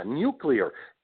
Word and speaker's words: nuclear: So it is nuclear, like new and clear, right?